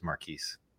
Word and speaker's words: Marchese: So I'm used to Cheese, but the actual way I pronounce it is Marquise.